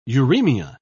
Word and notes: uremia: British English

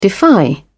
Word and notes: defy: British English

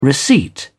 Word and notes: receipt: British English

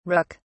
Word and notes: rook(s): British English

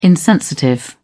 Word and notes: insensitive: British English

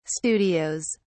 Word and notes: studios: British English

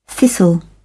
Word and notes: thistle: British English

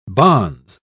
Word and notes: bonze: British English

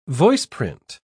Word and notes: voiceprint: British English

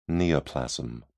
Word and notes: neoplasm: British English

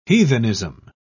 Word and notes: heathenism: British English

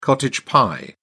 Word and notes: cottage pie: British English

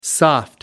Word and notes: soft: American English

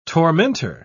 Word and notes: tormentor: British English

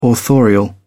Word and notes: authorial: British English

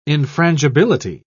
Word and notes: infrangibility: British English

